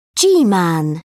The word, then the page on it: g-man: British English